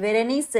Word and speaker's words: Berenice: Y Berenice se escribe con una E al final.